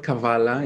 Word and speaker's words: Kavala: The town I'm from is called Kavala.